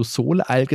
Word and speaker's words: Soleil: Hast du Soleil gesagt oder hast du hm. das Französisch ausgesprochen? Und seine Antwort war Französisch, warum?